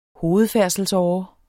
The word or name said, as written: hovedfærdselsåre